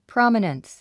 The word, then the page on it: prominence: prάmənəns; プロォミナンス